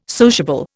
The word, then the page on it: sociable: Indian